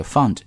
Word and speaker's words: fund: 然后大家在读音的时候注意一下，这个 fund 一定要窄一些，而 round 是 out 这个音啊。